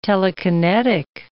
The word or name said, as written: telekinetic